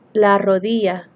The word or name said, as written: la rodilla